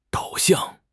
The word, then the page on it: 导向: HSK6